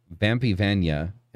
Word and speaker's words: Vampyvaina: so, so um, that being said, um, welcome Vampy Vanya. Uh, uh, did I say that correctly? – Yes. – Okay. – Well, um, it's like it's like Castlevania.